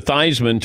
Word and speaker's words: Theismann: His name was Theismann. They changed it to Theismann to rhyme with Heisman.